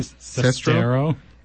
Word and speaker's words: Sestero: Sestero.